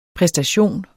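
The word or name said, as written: præstation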